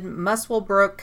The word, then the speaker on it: Muswellbrook: The family moved back to Aberdeen that year, and Catherine attended Muswellbrook High School. – Good pronunciation. – Thank you. Didn't know I needed... – Let me try. Muswellbrook.